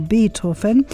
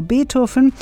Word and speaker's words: Beethoven: Die Station Radio Beethoven, so spricht man Beethoven auf Spanisch aus, also Radio Beethoven, wurde 1981 von den Musikern Fernando Rosas Pfingsthorn und Adolfo Flores Sailor ins Leben gerufen.